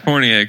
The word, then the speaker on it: Fournier: You pronounced Fournier correctly, and then you call Bismack Biyombo Boyimbo.